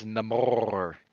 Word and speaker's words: Namor: It's Namor. You got to roll the R. And they're making a big deal out of it, actually. Like, no, you can't say Namor. It's Namor.